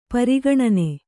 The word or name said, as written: ಪರಿಗಣನೆ